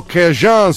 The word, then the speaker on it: Cajun: Ci ascoltiamo la musica che è caratteristica, certo c'è il rhythm and blues, c'è lo zydeco, ma c'è anche il Cajun o Cajun, se vogliamo essere davvero fedeli anche alla pronuncia francofona di quei luoghi.